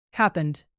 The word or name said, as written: happened